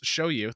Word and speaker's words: Zhao Yu: I am so hyped for Tekken 8, playing my favorites, Zhao Yu. Pronounce show you. Thank you for putting that in there. But show rhymes with cow.